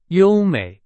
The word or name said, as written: 优美